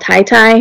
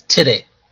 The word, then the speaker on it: Ty Ty: There's this place just down the road, and it's called Tai Tai, or it's spelled T-Y-T-Y, and I always assumed it was Tai Tai. So I go, is Tai Tai Tai Tai, or is it T-T? – It's Titty.